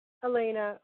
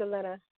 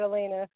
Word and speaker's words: Elena: Elena, or in Spanish it's Elena, but Elena.